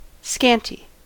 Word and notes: scanty: American English

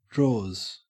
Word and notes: drawers: Australian English

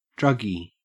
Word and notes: druggie: Australian English

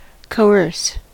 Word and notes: coerce: American English

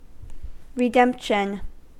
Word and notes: redemption: American English

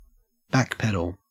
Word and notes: backpedal: Australian English

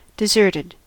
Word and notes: deserted: American English

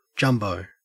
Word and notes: jumbo: Australian English

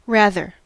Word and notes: rather: American English

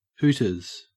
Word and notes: hooters: Australian English

